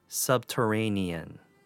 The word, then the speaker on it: subterranean: Next word, subterranean, five syllables. Sub sounds like the sub from Subway. Ter has your typical ER sound supported by the letter T. A just has a long A sound. Ni has a long E sound, and N has the same sound as the letter N, subterranean.